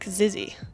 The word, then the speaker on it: XXYYXX: The artist's name is spelt XXYYXX, but there's some discussion as to whether you're supposed to pronounce it like that, or whether it's said like Zizzy. But Marcel himself pronounces it as XXYYXX in his interviews, so it's probably more correct to use that pronunciation.